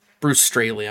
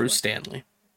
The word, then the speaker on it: Straley: Bruce Straley, I'm sorry, I pronounced his name wrong. – What'd you call him before? – Bruce Stanley.